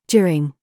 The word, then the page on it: CURE: British English